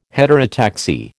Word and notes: heterotaxy: American English

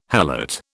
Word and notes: helot: British English

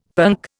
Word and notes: punk: Indian

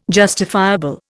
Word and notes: justifiable: Indian

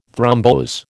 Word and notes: thrombose: American English

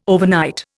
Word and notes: overnight: Indian

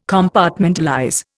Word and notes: Compartmentalize: Indian

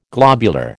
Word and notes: globular: American English

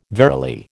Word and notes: verily: American English

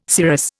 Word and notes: cirrus: Indian

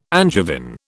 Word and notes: Angevin: British English

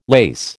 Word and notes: lase: American English

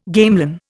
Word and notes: gamelan: Indian

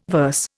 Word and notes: verse: Indian

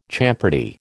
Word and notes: champerty: American English

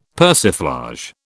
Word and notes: persiflage: British English

